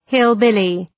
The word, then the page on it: hillbilly: ‘hıl,bılı